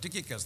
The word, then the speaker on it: Tychicus: And Ephesians and Colossians and Philemon were written at the same time and carried by Tychicus or Tychicus. That's how you spell it. Tychicus, that's how I say it.